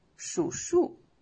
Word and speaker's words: 数数: 我们还有一个词啊，常用的是念发，叫发小，啊，表示从小就认识啊，从小就非常的默契啊，很好的朋友啊，叫发小，一起长大的这种哈、啊。嗯，有的时候呢，两个多音字会前后挨在一起组成一个词，比如说数数，数数，那钉钉子，